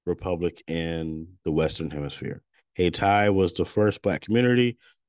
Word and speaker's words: Hayti: Haiti is pronounced Hay-Ti, so I pronounced it wrong. So it's Hay-Ti.